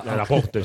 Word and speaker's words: Laporte: Ahora, si no es mucho pedir, a por Laporta. Y porque por no Asensio... – Laporte, sí. Lo he intentado decir en francés, por favor. Lo he dicho mal.